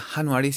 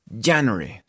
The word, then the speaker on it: January: Primero que todo, en inglés, la J no suena como una J, sino suena como una Y. Entonces, la pronunciación de este mes no sería January, sino ya, January, ¿sí?